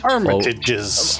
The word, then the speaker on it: Armitage: Is that how you pronounce it? That's Armitages. – Better than I would have got with.